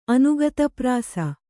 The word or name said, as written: ಅನುಗತಪ್ರಾಸ